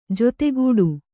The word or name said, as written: ಜತೆಗೂಡು